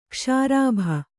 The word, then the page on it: ಕ್ಷಾರಾಭ: kṣārābha